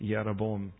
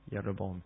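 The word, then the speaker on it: Jeroboam: When the kingdom divides, the 10 northern tribes follow under a guy under the tribe of Ephraim named Yeroboam, with a J. You say it like Y, Yeroboam.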